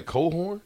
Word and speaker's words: Cochran: Did I say Cohorn? – Yeah, that's how it's pronounced. – Is it spelled like that? – It is. – C-O-C-H-R-A-N. – I think that's how it's spelled. Yeah. – Come on, dog. That looked like Crohan. – Cochran. – Yeah, it does. – That don't look like Colan. That's how you spell it, though? – Yeah, that's how you spell it.